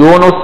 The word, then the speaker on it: يونس: ويونس هو يونس بن متى، ويجوز تثليث النون، يعني تقدر تقول يونس بضم النون، ويونس بفتح النون، ويونس بكسر النون، يعني يجوز فيها تثليث النون نطقا عربية، تقول يونس ويونس ويونس، يقول لك تثليث النون يعني تبقى بالضم والفتح والكسر.